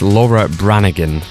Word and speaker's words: Laura Branigan: I've also realised I've been saying it wrong. It's Laura Branigan.